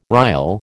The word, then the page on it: rile: American English